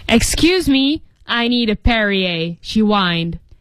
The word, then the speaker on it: Perrier: 注意读音叫 Perrier.